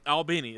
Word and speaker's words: Albany: Albany. That's the right way you say it.